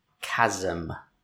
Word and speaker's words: chasm: Yeah, if you look on the line, it's the pronunciation is K-A-Z-E-M. Chasm.